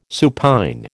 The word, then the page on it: supine: American English